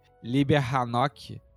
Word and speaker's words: Liber Enoch: Ó, oh, vou dar um spoilerzaço que Liber Hanok é porque como se pronuncia Liber Enoch.